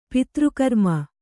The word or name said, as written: ಪಿತೃಕರ್ಮ